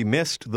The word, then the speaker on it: missed: We missed the bus. Missed. – Noti che il suono delle lettere finali E, D è un po' diverso. Dopo una certa combinazione di lettere, il suono D si pronuncia T.